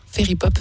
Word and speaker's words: Faerie Pop: Alors ça s'écrit F-A-E-R-I-E-P-O-P. Fairy Pop Festival, c'est pour ça.